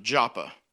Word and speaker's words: Joppa: In Hebrew, it would be called Jaffa. So if you would read the Old Testament, you might see it spelled as J-A-F-F-A, Jaffa. In the Greek, it's written and pronounced Joppa.